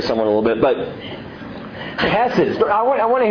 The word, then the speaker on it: chesed: The great Hebrew word translated kindness here is chesed. Chesed. If you spell it out, if you're writing an old transliteration, spell it with a CH. It's an H in the Hebrew, but it makes that sound.